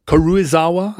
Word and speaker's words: Karuizawa: Karuizawa, I guess, is how it's pronounced.